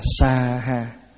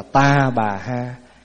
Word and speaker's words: sa ha: Câu cuối cùng là sa ha, có khi đọc là ta bà ha, có khi đọc là xóa ha,